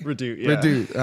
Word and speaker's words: Redoute: It's been a while since we've done one of these, and that's why I had to bring a very special guest, Mr. Ryan Redoute. Did I say that right? – Redoute.